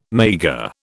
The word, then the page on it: maigre: British English